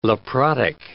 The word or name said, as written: leprotic